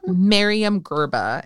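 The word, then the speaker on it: Myriam Gurba: Miriam Gerba. And the last name is spelled G-U-R-B-A. First name M-Y-R-I-A-M. Miriam, common spelling, Gerba, G-U-R-B-A.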